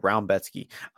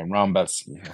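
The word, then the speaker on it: Rębecki: Is it like, Rebecki or Roumbetski? – I'm pretty. When his last fight, they pronounced it Roumbetski. – Okay, Roumbetski. Okay.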